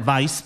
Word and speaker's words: vice: Ale někteří lidé, když se to píše, tak oni si tam pletou při výslovnosti, protože anglicky se řekne vice president. Oni si to pletou se slovem wise, kde je dvojité V, což znamená moudrý.